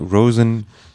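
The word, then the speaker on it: Róisín: Nee, wird R, dann ein O mit Apostroph, I-S, I mit Apostroph, I-N, aus Irisch, Roisin, geschrieben. – Gut, zu viele Apostrophe. Hat aber, glaube ich, ja. Roseanne ausgesprochen, Roseanne Murphy, in Kollaboration mit DJ Kotze.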